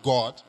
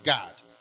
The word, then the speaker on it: Gad: From the tribe of Gad, not God, but Gad. Now, when I was reading this, I remember other people pronounced God as God, but this one is God with G-A-D.